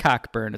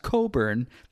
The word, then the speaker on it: Cockburn: It's spelled like Cockburn. It's pronounced Coburn.